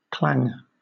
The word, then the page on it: clang: British English